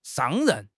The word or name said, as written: 上任